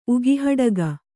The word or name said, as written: ಉಗಿಹಡಗ